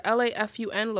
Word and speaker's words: lafun: L a f u n lafun simply means dried cassava flour.